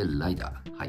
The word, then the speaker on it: LIDAR: ライダー、 LIDAR と書いてライダー。